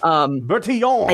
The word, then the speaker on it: Bertillon: I think you're throwing an extra I in there, which is easy because you've got a lot of I's and L's and it's French. – Fair enough. Bertillon.